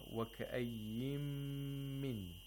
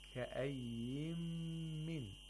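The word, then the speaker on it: وَكَأَيِّن مِن: An example in this verse is actually وَكَأَيِّمْ مِنْ. Okay? كَأَيِّمْ مِنْ. It's كَأَيِّمْ. But the Nun Sakina is omitted.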